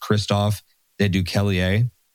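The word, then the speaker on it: Christophe De Beukelaer: I believe his name is pronounced Christophe de Duquelier, and he is officially the first EU politician to take his salary in Bitcoin.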